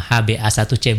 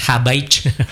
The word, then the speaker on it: HbA1c: Jadi bacanya gitu ya, disebut semua HbA1c, bukan Habaich.